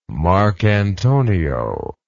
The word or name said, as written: Marcantonio